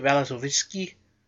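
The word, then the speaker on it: Vasilevskiy: But it didn't come easy, because Ben Bishop, the goalie for Tampa Bay, came out in the second period, and Andrei Valasovsky, that's how you say his name, the 20-year-old rookie Russian, had to come in and play the remainder of that game.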